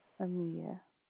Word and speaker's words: Amia: Amia. – Spell it. – A-M-I-A. – A-M-I-A? – Mm-hmm.